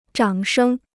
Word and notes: 掌声: zhǎng shēng

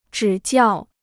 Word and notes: 指教: zhǐ jiào

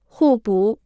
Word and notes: 互补: hù bǔ

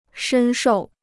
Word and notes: 深受: shēn shòu

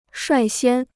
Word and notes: 率先: shuài xiān

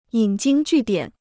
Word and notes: 引经据典: yǐn jīng jù diǎn

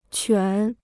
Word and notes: 犬: quǎn

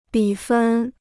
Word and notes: 比分: bǐ fēn

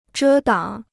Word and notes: 遮挡: zhē dǎng

